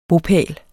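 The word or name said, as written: BOPÆLEN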